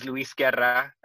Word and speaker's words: Luis Guerra: Luis Guerra. So I'm American. My father, my name's Luis Guerra, and I take a Spanish pronunciation, and my last name, you know, it's Spanish, and Luis.